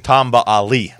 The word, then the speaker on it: Hali: Hali? How do you say his name? – Tamba Ali.